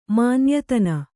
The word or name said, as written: ಮಾನ್ಯತನ